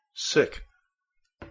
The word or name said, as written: sick